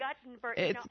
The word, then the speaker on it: Guttenberg: Sandy mailed in, please correct the pronunciation of the town of Guttenberg. It's not the same as the inventor. – It's Guttenberg. I should remember that.